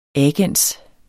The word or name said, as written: agens